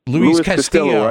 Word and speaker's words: Castillo: like I'm pronouncing his name right, Castillo. How you say it? Castillo? – Luis Castillo, right? Yes, Luis Castillo.